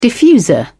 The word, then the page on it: diffuser: British English